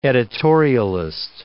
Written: editorialist

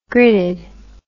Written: gridded

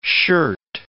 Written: shirt

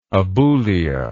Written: aboulia

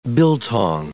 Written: biltong

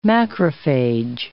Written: macrophage

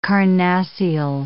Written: carnassial

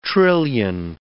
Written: trillion

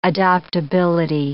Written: adoptability

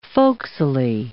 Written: folksily

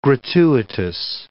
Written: gratuitous

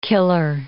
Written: killer

